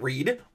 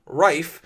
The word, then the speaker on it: Reif: Riley Reif. Not Reed. Reif.